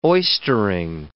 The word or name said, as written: oystering